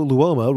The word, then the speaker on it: Luoma: From TJ, friend of the show, Luoma rhymes with diploma.